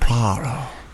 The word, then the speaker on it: Poirot: Poirot. – Poirot.